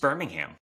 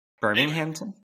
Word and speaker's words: Birmingham: I think it's just Birmingham. – Birmingham? Okay. – You threw an extra syllable on there. – I did. – Birminghamton? – Okay.